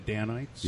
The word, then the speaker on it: Danites: I'm saying that right, aren't I? The Danites? – Yep. Yeah. Yep.